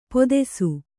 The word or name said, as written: ಪೊದೆಸು